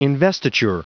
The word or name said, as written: investiture